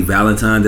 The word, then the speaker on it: Valentin: On my boy Bradley Valentin.